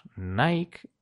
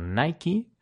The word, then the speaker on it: Nike: La marca deportiva Nike bueno, en España la pronunciamos Nike, aunque debería ser Nike.